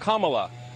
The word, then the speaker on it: Kamala: Her name is pronounced comma, like the punctuation mark. Look, Kamala.